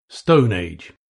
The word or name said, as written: stone age